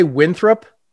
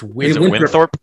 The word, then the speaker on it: Winthrop: It's like you always want to say Winthrop, but it's Winthrop.